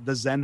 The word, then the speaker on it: Xen: And I should say it's called the Zen Harmonic Alliance. Uh-huh. And Zen Harmonic is spelled with an X. So X-E-N. – Okay. – X-E-N Harmonic.